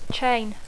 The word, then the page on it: chain: English